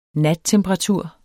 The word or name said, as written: nattemperatur